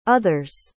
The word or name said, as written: other's